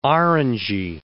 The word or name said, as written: orangy